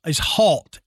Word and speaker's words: Halt: Now, I just realized, Adam, that in my notes, I have got Hart and Halt written out, and mm-hmm. I have been saying it both ways, but I think I've said Hart more than Halt. The correct is Halt, H-A-L-T.